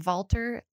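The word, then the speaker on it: Valter: So if anyone is interested in that information, Walter, like sounds like Walter, but it's spelled with a V, Walter Longo, L-O-N-G-O.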